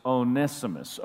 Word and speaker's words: Onesimus: I've been calling him Onesimus, but I actually looked it up, and it's Onesimus, Onesimus.